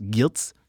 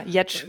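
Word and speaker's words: Giertz: ich spreche sie deutsch aus, Girtz. – Also, Girtz, ja, Jetsch wird es, glaube ich, ausgesprochen, ja.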